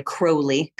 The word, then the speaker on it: Crowley: Sorry, Crowley, got to get the pronunciation right. We Americans tend to say it wrong.